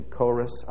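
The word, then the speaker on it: choris (χωρίς): It's a modern pronunciation, choris, apart from or without works.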